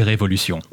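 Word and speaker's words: Révolution: Technically, you can say both, but the way it's spelled is the French way, so it would be Revolution.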